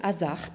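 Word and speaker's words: Haesaerts: Il s'agit de l'historien et conservateur belge Paul Hazards, c'est ainsi qu'on prononce, qui en 1947 a fait paraître un Renoir sculpteur, voici l'édition euh, anglaise, euh, qui le premier a vraiment euh, mené l'enquête.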